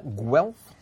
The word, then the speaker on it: Guelph: Guelph is the right way to pronounce it.